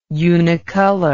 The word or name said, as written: unicolor